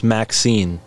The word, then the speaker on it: MXene: They said it's pronounced Maxine, like the name, Maxine. Like the female name Maxine.